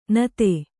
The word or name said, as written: ನತಿ